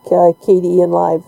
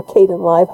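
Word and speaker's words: Kdenlive: Yeah, so hopefully nothing has happened to him, and that the project will continue, because I do like KDE and Live. It's a, or Caden Live, however you'd like to pronounce it, because it's a nice, efficient little video editor.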